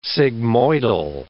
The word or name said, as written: sigmoidal